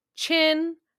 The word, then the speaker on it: Qin: I want to say Chin. Listen, it's not my ancestors we're disgracing, so. – Dishonor on you, dishonor on your cow, dishonor on your whole family. Sounds like Chin.